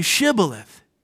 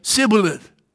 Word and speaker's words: Shibboleth: If he said no, then they would say to him, then say Shibboleth. Now the word meant flowing stream. And he, that is the escaping Ephraimite, would say Sibboleth. For he could not pronounce it right.